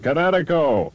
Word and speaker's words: Kinetico: Everybody knows that Kinetico is spelled K-I-N-E-T-I-C-O. – I thought the instructor said Connecticut. Oh, the East Coast, son, you should have remembered kinetic as in kinetic energy with an O on the end. Kinetico. – Kinetico. Spell it K-I-N-E-T-I-C-O.